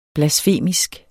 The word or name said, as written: blasfemisk